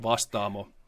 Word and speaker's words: Vastaamo: Yeah, you actually did a pretty good job of it. It's pronounced Vastaamo. Okay.